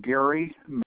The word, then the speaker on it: Gary: Gary Manley. – And that's G-E-R-R-Y? – G-A-R-Y. – G-A-R-Y. – M-A-N-L-E-Y.